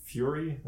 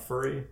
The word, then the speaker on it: Furi: I think it's pronounced Furi, not Furry, right? – It depends on where you're from. – But okay. Yeah. Mm-hmm. Spelled F-U-R-I.